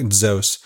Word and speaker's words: Zeus: It, you've heard Zeus. The correct pronunciation is more like Zeus.